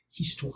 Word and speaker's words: histoire: Histoire.